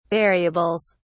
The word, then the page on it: variables: British English